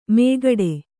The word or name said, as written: ಮೇಗಡೆ